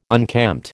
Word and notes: unkempt: American English

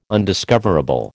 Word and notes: undiscoverable: American English

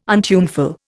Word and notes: untuneful: Indian

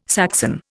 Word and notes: Saxon: Indian

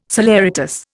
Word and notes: saleratus: Indian

